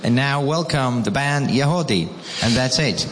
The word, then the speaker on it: Yagody: Y-A-G-O-D-Y, but it's pronounced Yahodeh.